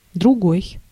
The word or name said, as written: autre